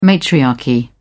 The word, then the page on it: matriarchy: British English